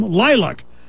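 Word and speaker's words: LYLVC: That band is called Lylac, though they spell it L-Y-L-V-C. L-Y-L-V-C, but it's pronounced, according to them, Lylac.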